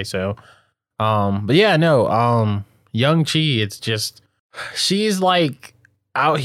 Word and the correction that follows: Young Hee: I said it wrong. Young Lee, right? – Fuck, did you even look at the wiki? – This is going to be progressively worse. Like, Young Hee. – There we go. Yeah. Young Hee.